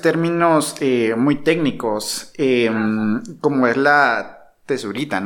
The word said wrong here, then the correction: tesitura: no sé si lo estoy pronunciando bien. – ¿Tesitura? – Tesitura, ok, perdón, perdón.